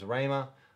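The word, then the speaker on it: rhēma: And as if anyone's familiar with this, the H is silent, so it becomes Rahima. Rahima.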